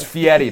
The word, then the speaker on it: Fieri: It's Fieri. That's how you actually say it.